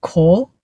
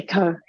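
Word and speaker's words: Ko: His surname's translated to K-O, which most people will presume to pronounce a Kuo or Guo. – Yeah, Kerr. – Especially Kerr. Yeah, Kerr.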